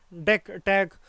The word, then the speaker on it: DECTEC: or DECTEC, pronounced DECTEC for short.